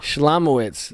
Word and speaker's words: Shlomowitz: Shalamowitz? – No, it's not Shalom. It's Shlom. – Shlomowitz. – There's no A.